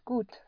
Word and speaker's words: gut: The gut is spelled G-U-T. The you always is pronounced like a double O in English, like in food.